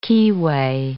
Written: keyway

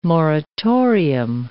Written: moratorium

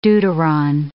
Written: deuteron

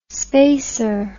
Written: spacer

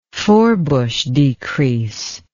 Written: Forbush decrease

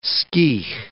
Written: skeigh

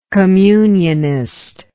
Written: communionist

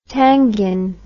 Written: tanghin

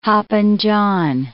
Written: hopping John

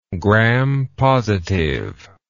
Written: gram-positive

